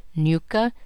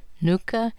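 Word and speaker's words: Ngcuka: Pronunciations found online vary wildly. Versions used by officials and reporters include Nuka, Nuka, Nkuka.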